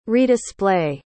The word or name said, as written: redisplay